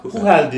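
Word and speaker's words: Chuchel: Biz Çuçıl falan diyorduk ona ama Huhel diye telaffuz ediliyormuş Çekçe'de.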